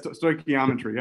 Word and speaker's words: stoichiometry: People Did I pronounce it right? – Yes, stoichiometry.